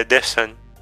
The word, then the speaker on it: dessin: em francês é dessin. – Dessin. – Seria a pronúncia.